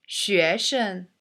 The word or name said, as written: 生